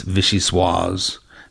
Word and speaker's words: vichyssoise: You've got to have a meeting with your whole staff please explain to them it's vichyssoise, that you do have that final sibilant syllable.